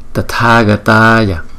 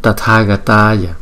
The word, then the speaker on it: Tathagataya: Tathagataya Tathagataya Tathagataya Tathagataya. Chữ T và chữ H chúng ta đọc là tờ hờ tờ tờ.